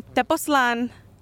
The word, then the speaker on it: Tepoztlán: I always say it wrong. I say it different every single time. – Yeah. – We are in Tepoztlán, Mexico right now. I needed to say it with all the accents because I know one of you bitches would say something dirty about me if I didn't.